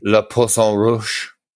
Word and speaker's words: Le Poisson Rouge: Le Poisson Rouge.